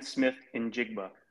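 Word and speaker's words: Smith-Njigba: Yeah, and it's pronounced Jackson Smith and Jigba.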